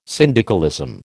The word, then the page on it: syndicalism: American English